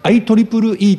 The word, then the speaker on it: IEEE: で右下のところでちょっとまあ見えづらいかもしれないんですが、 IEEE って書いてですね、これ IEEE って読みます。